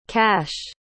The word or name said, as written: kasch